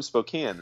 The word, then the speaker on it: Spokane: You're from Spokane, right? – Yes, and you pronounce it beautifully.